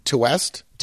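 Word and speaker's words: Ti West: It's, it's by T West or Ty West, however you pronounce that guy's name. He sort of. I think it's pronounced to West.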